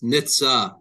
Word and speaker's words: Nitze: It's Nitza, like Porsche. Nitza. Okay.